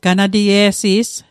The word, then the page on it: ganatie'si's: Mi'gmaq